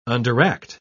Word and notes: underact: British English

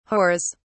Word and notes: whores: British English

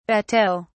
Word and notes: bateaux: British English

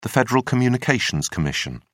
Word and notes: federal communications commission: British English

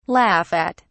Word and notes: laugh at: British English

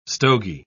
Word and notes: stogy: British English

